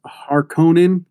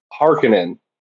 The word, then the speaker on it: Harkonnen: Did you read it as Harkonnen, like in the movie? – That's how I read it, yeah. But I noticed the audio pronounced it like Harkonnen. – Harkonnen, that's what's in my head, yeah.